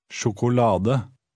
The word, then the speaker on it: sjokolade: Try 11, stressing the next to last syllable. – Sjokolade.